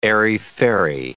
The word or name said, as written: airy-fairy